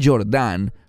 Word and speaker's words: Jordán: Ahora lo pronuncié Jordán porque proviene de una palabra hebrea, Yorad o Leredet en infinitivo. Y eso significa descender. Esa palabra es una palabra de humildad.